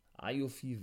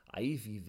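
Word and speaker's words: Aoife: Und, äh, Ayofi Valkyrie, aber ich glaube, die wird ausgesprochen Ivy Valkyrie.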